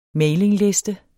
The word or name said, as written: mailingliste